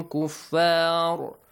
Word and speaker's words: كُفَّارٌ: The rule, إخفاء must be applied here. It means you should hide the sound of the tanween. وَهُمْ كُفَّارٌ. I told you to not pronounce it completely, yes? رُن, when you pronounce the noon sound, the tongue touches the gum area at the top. – Yeah. – كُفَّارٌ. Do not let it touch there.